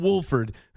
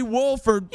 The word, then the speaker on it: Wolford: Yeah, John Wolford, who nobody even knows his actual name, and he dodges the own pronunciation. He's like, yeah, you kind of say the L.